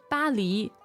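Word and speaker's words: Bali (巴黎): Well, I know the pronunciation of Paris in Chinese is very close to the French pronunciation. – Okay. – Bali.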